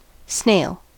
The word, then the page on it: snail: American English